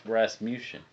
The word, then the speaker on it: Rasmussen: Rasmussen.